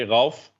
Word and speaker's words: Ralph: Typical Dutch way, Ralph.